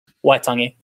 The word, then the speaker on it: Waitangi: The first reference to the Treaty of Waitangi, how do you pronounce that? – Waitangi.